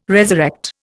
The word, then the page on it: resurrect: Indian